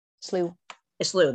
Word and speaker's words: Slough: and Steamboat Slough in Everett. – Slough, there we go. I was like, I know I said that wrong. Because it doesn't, it's not spelled. – It's a weird spelling.